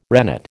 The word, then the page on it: rennet: American English